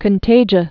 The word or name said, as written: contagia